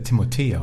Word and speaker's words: Timothée: Ich habe letztens gelernt, dass der Timothée ausgesprochen wird. – Ja. – Weil ein Franzose ist.